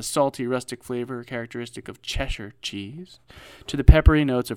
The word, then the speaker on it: Cheshire: Cheshire is pronounced Cheshire. – Yeah, I think I pronounced it that way.